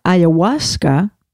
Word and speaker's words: ayahuasca: It says here that she had suicidal thoughts when she was in her 40s, and she says that taking ayahuasca, which is a hallucinogen, that's how you say it? – Yeah.